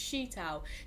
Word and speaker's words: Sheetal: And I was like, "Oh, don't worry, it's Sheetal."